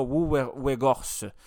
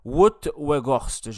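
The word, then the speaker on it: Wout Weghorst: Euh, en attaque, on avait euh, trois joueurs offensifs avec Marcus Rashford, Bruno Fernandes, Anthony le Brésilien et l'attaquant euh, des Pays-Bas, Wuwergost, Pardon. Vous savez que la prononciation des Pays-Bas, des fois, là, c'est un peu difficile en irlandais. donc c'est Wout-Weghorst, je crois qu'on prononce.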